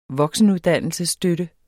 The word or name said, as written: voksenuddannelsesstøtte